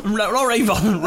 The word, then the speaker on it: Ruabon: As always, in addition, despite my name and the fact I live in Raybon near Wrexham, I think it's Raybon. – Raybon? – Raybon. Raybon. It's spelled R-U-A-B-O-N. Raybon.